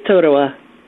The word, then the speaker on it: Totowa: Is it Potowa, New Jersey, or Potowa? I never know how to pronounce it. – It's Totowa. – Totowa, gotcha.